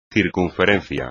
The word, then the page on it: circunferencia: espagnol